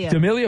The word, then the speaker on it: D'Amelio: Dixie D'Amelio. Is that saying that right? 25. Is that an actress? – D'Amelio.